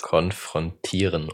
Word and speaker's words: konfrontieren: Konfrontieren, ohne das dritte R.